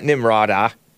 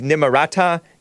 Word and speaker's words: Nimarata: She had both a Sikh, S-I-K-H, pronounced either way, I guess. I don't know. Wedding and a Christian wedding. Nimarata. It's not Nimrata. It's Nimarata.